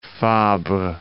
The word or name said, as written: Fabre